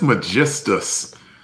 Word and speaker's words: Magistus: Was it Magistus? Megagist? – I got that last syllable, and I think you got it right. I think it's Magistus.